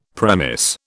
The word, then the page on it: premiss: British English